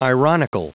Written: ironical

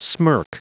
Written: smirk

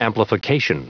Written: amplification

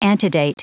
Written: antedate

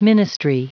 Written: ministry

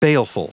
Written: baleful

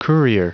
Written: courier

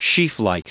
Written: sheaflike

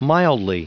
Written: mildly

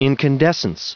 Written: incandescence

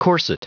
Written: corset

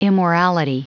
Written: immorality

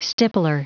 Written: stippler

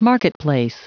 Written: marketplace

